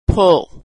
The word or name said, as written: pull